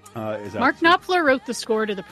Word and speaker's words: Knopfler: London Suede, The Rag Picker's Dream by Mark... Knopfler? – Knopfler. You know Mark Knopfler.